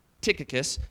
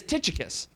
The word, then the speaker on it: Tychicus: And technically the pronunciation is Tychicus, but that's just too hard for me to say. So it's just if you say Tychicus, I'm proud of you, okay?